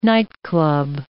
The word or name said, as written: nightclub